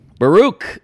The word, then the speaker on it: Baruch: Is that how you say it? – Baruch.